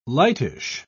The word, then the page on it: lightish: British English